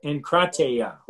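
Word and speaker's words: enkrateia: Inkrateia, let me say that right. Inkrateia.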